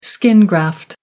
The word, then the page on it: skin graft: American English